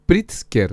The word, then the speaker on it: Pritzker: Pritzker.